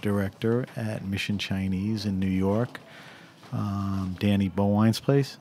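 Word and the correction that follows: Bowien: Is that the right way to pronounce Danny? – Bowen.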